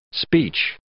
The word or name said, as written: speech